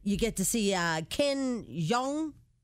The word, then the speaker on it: Jeong: Jeong. – It's J-E-O-N-G. – J-E-O-N-G. – Jeong. – Jeong. Jeong. – Sean, how do you say his last name? – Jeong. Jeong.